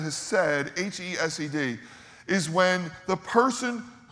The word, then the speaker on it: hesed: He studied the Bible all his life, and he's had this love affair with this Hebrew word, hesed. And to say it right, you have to like hock one up, like hesed.